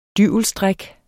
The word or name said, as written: dyvelsdræk